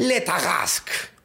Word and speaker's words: Le Tarasque: ¿Qué sería la forma apropiada de, pronunciarlo, no? – Muy elegante, además. Le Tarasque.